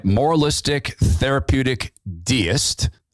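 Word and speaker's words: moralistic therapeutic deist: Tell me if I say this right. Moralistic, therapeutic deist. I got that right.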